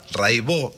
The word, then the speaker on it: Raibaut: Buenísimo, Cata Raibó. Se escribe Raibaus, métanse, búsquenla, Rai con Y, Baut con B larga. Catalina Raibaut, Raibó se dice, y bueno, Cotidiano son 10 canciones, hay un documentalito muy lindo.